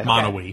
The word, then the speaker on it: Monowi: Oh, it's spelled M-O-N-O-W-I, okay. – Monowee.